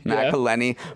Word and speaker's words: McElhenney: McElhenney bought this club, and then they basically just turned it into content.